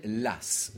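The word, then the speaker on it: Law: Bon, ben, L'eau, à la cour, on l'appelle l'as.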